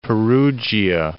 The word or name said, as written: Perugia